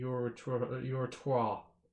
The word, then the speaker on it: uritrottoir: It's pronounced the urethro. There we go. It's French.